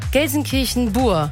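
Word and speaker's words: Gelsenkirchen-Buer: Gelsenkirchen-Buhr. Spricht man Buhr aus, obwohl es mit UE geschrieben wird?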